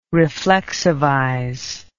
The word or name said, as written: reflexivize